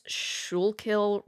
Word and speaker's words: Schuylkill: on the banks of the, I'm going to butcher this word, I think it's Schuylkill River. – Yeah, that sounds good. – It's a very hard word to pronounce. There's a lot of Y's and CH's.